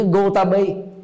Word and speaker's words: Gotami: Ví dụ như Gotami, người Ấn Độ phát âm Gotami, chúng ta cũng phát âm được Gotami, nhưng mà người Trung Quốc không phát âm được Gotami. Phát âm là Kiều Đàm Nhi.